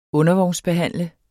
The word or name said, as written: undervognsbehandle